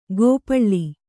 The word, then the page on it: ಗೋಪಳ್ಳಿ: gōpaḷḷi